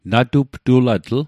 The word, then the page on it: natuptulatl: Mi'gmaq